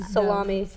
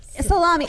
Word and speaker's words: Salome: But another woman who tried to promote her own children was Salome, okay? And I know that sounds like, did I say it wrong? – Salome. – Salome. I know, we think lunch meat here. Her name is Salome.